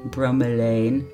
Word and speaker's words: bromelain: It is a noun, and it is pronounced bromelain.